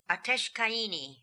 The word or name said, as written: Ateshkaini